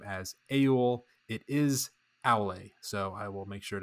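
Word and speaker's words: Aulë: Valar Aule, and I have mispronounced his name. I've called him Aul.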